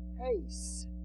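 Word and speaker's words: heis (εἷς): So the Greek word here, one, is pronounced heis, H-E-I-S.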